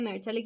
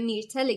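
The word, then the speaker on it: Myrtle: مثل مرتل گریان که تو ترجمه میرتل گریان عنوان شده، تو داستان‌های حاشیه‌ای هر قسمت این اصلاحات رو اعمال کنید.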